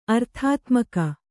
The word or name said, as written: ಅರ್ಥಾತ್ಮಕ